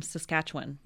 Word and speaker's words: Saskatchewan: I just learned that you're all from Saskatchewan.